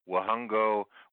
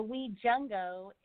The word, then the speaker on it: Wajungo: And whenever I see a J in a company name, I don't know whether to go Wahungo, Wajungo. So please, let's start with the name of the company. Where did it come from and how do you properly pronounce it? – Yeah, you were close. So Wajungo is actually partly from the Latin term jungo, which means join together.